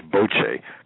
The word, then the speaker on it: bocce: We said, if you allow the society to build bocce ball courts, and really the proper pronunciation is bocce, because bocce really means kiss,